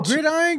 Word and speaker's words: Gridiron: Gridland Gangs, man. Let me see. Yeah, bro. – Oh, Gridiron Gang? – Gridiron. Okay, I'm saying it wrong.